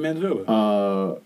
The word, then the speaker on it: Manitoba: It is still brewed where it was originally brewed, off the shores of Lake Winnipeg in Manitoba. I'm probably saying that wrong. – No, you're saying Manitoba.